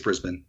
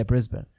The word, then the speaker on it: Brisbane: How do you say Brisbane? – I think it's Brisbane. – Yeah, Brisbane.